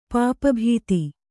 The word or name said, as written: ಪಾಪಭೀತಿ